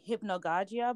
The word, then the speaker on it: hypnagogia: I was looking up, I don't know if you heard of it, you probably have, like, I don't know if I'm going to say it right, hypnagogia, I believe? – Correct, yeah.